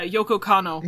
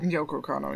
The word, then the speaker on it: Kanno: Yeah, Yoko Kanno. – Yoko Kanno, yeah.